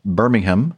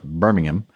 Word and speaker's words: Birmingham: Antonov 26 at Birmingham, not Birmingham, but Birmingham, England, on the 16th of July, 2020.